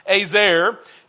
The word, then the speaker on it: ezer: It's pronounced azer, E-Z-E-R, and it's a Hebrew word that means one who surrounds, protects, or aids.